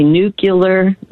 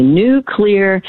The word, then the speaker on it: nuclear: And then the third one that people mispronounce all the time is when they say nuclear, not correct. So if you, it's cloudy today but tomorrow it's going to be a nuclear day. Just say nuclear and you will pronounce nuclear correctly.